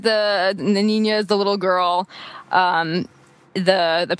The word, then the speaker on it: niña: Yeah, I mean, you would just pronounce them in the Spanish, so with the I-U-A-O pronunciation, but it should be niña, like girl in Spanish.